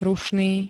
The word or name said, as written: rušný